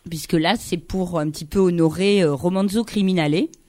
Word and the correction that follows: Romanzo Criminale: Je suis sûre que je prononce très mal. – Romanzo Criminale. – Ah oui, c'est beaucoup mieux comme ça.